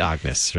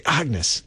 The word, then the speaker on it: Agnus: And Agnus, for those of you who are spelling it, A-G-N-U-S, correct? – Yes. It's Latin. – It's Latin. Some pronounce it Agnus, right? – Yes. It's Agnus. Really. – I can't. I'm a singer. I was always instructed to pronounce it Agnus, and I can't get past it. I can't say Agnes.